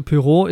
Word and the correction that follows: Poirot: ist halt, nicht Pyro. wie spricht man es denn aus? Poirot? – Poirot, sagen Poirot. wir einfach Poirot.